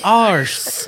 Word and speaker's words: Arce: Her last name is A-R-C-E. That's how you pronounce it. – Tiffany Arse.